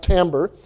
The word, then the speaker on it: timbre: It's spelled timbre, but B-R-E. It's pronounced timbre.